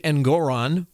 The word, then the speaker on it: Engoron: And this is exactly what happened with Judge Engoron, which rhymes with moron, last week.